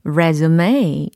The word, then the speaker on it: resume: R-E-S-U-M-E e 라는 철자죠. 어, resume 요 발음에 좀 집중하시면 좋을 것 같아요. 만약에 resume 라고 발음을 한다면 그거는 이력서에 해당하는 다른 명사가 되겠죠? 어, 근데 오늘은 철자는 동일해 보이지만 발음이 resume resume 요걸로 연습을 할 거고 의미는요.